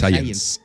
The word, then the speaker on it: Science: Science.